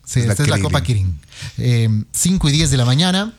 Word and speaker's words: Kirin: ¿Cómo se dice? Kirin. – Kirin. – Kirin. ¿No es Krilin? – No, Copa Kirin.